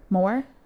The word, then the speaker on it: Moher: Cliffs of Moher? – Moher? – Yeah. – they don't pronounce the H.